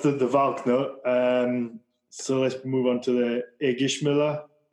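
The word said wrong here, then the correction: Ægishjálmur: Do you want to pronounce it properly? Since I probably butchered it. – Yeah. Eishalmur.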